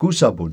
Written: kusapun

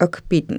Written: kpitn

